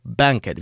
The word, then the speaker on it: BNQT: Wtedy premierę miał krążek Volume 1 od e, powiedziałbym BNQT, ale oni życzą sobie, żeby wymawiać to jako banket, więc tak wypowiadam.